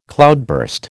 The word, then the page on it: cloudburst: American English